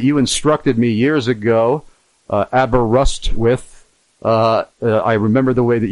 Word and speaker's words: Aberystwyth: I say Aberystwyth, and then you've got it.